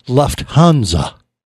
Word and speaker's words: Lufthansa: Lufthansa.